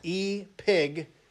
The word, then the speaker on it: epignosis: So in verse 3, our word knowledge is actually the word epignosis. Epignosis. It's spelled epignosis, but it's pronounced I-P-I-G, epignosis.